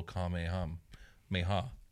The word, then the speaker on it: Kamehameha: Like you said, with its little Kamehameha. – That's how they say it in the show.